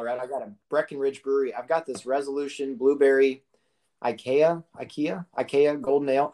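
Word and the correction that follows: açaí: How do you say that? A C A I. – Oh, acai.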